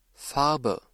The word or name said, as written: Farbe